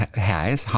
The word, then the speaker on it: heißen: Von Hase, das ist Haas.